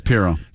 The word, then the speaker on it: Pirro: Attorney Janine Pirro, I think is how you say it. – It's Pirro.